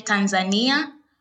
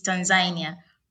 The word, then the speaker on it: Tanzania: So in Kiswahili, we would say Tanzania, but in English, as how I learned, it's Tanzania.